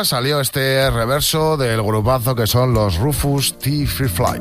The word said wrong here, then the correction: Rufus T. Firefly: Claro, claro, yo lo he dicho mal. – Claro, y él solo quería decir. – ¿Cómo se dice, Fernando? – Rufus T. Firefly.